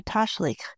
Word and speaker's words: Tashlich: I'd also been looking for an opportunity to try Tashlich. Listeners, that is spelled T as in Tom, A-S-H-L-I-C-H. It's a